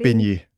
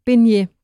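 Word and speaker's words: Besnier: Eller hvordan udtaler man det? – Benje. – Benje, tak.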